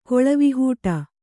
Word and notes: ಕೊಳವಿಹೂಟ: Kannada

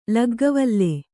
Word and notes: ಲಗ್ಗವಲ್ಲೆ: Kannada

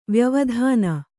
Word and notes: ವ್ಯವಧಾನ: vyavadhāna

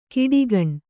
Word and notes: ಕಾಡ್ಗೋಣ: kāḍgōṇa

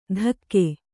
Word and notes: ಧಕ್ಕೆ: dhakke

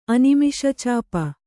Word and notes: ಅನಿಮಿಷಚಾಪ: animiṣacāpa

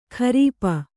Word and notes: ಖರೀಪ: kharīpa

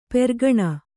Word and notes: ಪೆರ್ಕಣ: Kannada

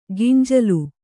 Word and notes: ಗಿಂಜಲು: ginjalu; kannada

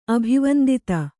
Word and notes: ಅಭಿವಂದಿತ: kannada

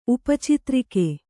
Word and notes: ಉಪಚಿತ್ರಿಕೆ: Kannada

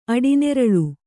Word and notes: ಅಡಿನೆರಳು: Kannada

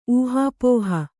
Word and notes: ಊಹಾಪೋಹೆ: ūhāpōhe

